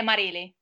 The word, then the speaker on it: Marili: Vamos lá, primeiro eu queria saber se eu estou pronunciando o seu nome correto. É Marile mesmo? – Isso, é, Mari... é Marile.